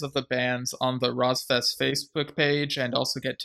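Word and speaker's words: Rosfest: Rozfest, and in parentheses, he says, by the way, you can pronounce it as Rozfest. I guess because on the episode, I was saying ROS Fest or something, but yeah.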